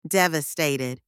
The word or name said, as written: devastated